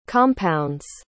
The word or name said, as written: compounds